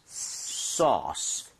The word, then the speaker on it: sauce: AU나 AW 스펠링이 나오면 O보다 조금만 입모양을 벌려준다. 아. 이 느낌. 그래서 소스보다는요. 소스. Sauce. 살짝만 벌리시면 되거든요.